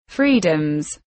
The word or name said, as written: freedoms